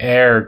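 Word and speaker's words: heir: Round categories, landmarks, top British baby names, some good news, arrivals and departures, hair guitar, or really air guitar, but spelled H-E-I-R. I tried to give an H because this is an audio medium, but it's not how it's pronounced.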